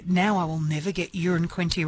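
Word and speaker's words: Uranquinty: It was Tambourine Bay Road, and I love this because Peter's texted in saying, I live in Urin Quinty. It's the same pronunciation as a number one, so it's not a big town. One could describe it as a wee place, and then a laughing emoji.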